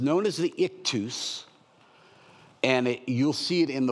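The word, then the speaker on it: Ichthys: That what I would say in English, I-X-O-Y-E, pronounced ictus, is Jesus.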